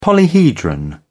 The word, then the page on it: polyhedron: British English